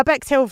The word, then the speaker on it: Bexhill: Now, Becks Hill, for anyone who is maybe outside of the UK or is unaware, it's not spelt the same as Beck Hill. Becks Hill is spelt B-E-X-H-I-L-L. as one word, Bexhill on Sea.